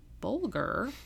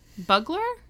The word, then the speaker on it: Bulger: Oh, it looks like Bugler. – Bugler? Did I write it? No, sorry. Switch the G and the L. It's supposed to be B-U-L-G-E-R. – Okay. – I promise. I didn't look up how to pronounce it, obviously.